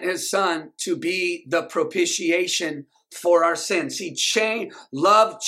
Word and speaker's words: propitiation: I can almost guarantee some of y'all didn't say it right. Because I have to say it slow to say it right. Everybody say that with me. Say propitiation.